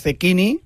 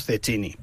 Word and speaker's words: Cecchini: Sí, pero bueno, en teoría es Cecchini. Uh-huh. Lo que pasa es que nosotros, como es más castizo, decimos Cecchini, pero realmente hay que pronunciar Cecchini.